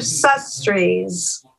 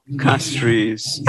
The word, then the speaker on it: Castries: The capital, Sestrees, did I pronounce it? Is it Castries or Sestrees? – Castries.